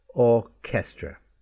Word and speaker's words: orchestra: They have a k sound. For example, orchestra. It is spelt O-R-C-H. Here's the ch, E-S-T-R-A. But the sound is k, orchestra. Cancel, C-A-N-C-E-E-L. Okay, chemistry, K, chemistry, C-H-E-M-I-S-T-R-Y, K, chemistry.